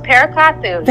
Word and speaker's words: Perakathu: That is very close, Perakathu.